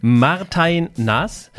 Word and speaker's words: Martijn Nas: Hallihallo und herzlich willkommen zu Folge 98,5 mit Martin warte mal, wie wird es richtig ausgesprochen? – Martin. Martin Naas.